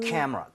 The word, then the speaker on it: camera: She bought me 여기에만 강세 주면 돼요. – camera. 오. – camera. 근데 진짜 무슨 이름절인 것처럼 이렇게 돼야 돼요. camera 나눠지는 순간 발음이 엄청 어색해집니다.